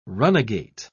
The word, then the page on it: runagate: British English